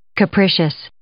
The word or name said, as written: capricious